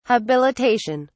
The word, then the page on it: habilitation: British English